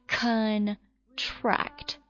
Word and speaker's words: contract: 它的读音放在重音在第二个音节上面了， contract。